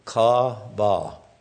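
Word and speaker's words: ka-ba (קָוָה): If you were to look up in Strong's Bible Concordance or another one, Young's, under the word wait, it's a Hebrew word, ka-ba. I would spell it K-A-W-V-A-W.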